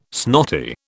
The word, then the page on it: snotty: British English